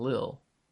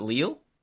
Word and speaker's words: Lille: How do you pronounce it if it's not Lil? Unless you're talking about something else. Is it Lille? – Yeah.